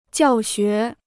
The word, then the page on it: 教学: jiào xué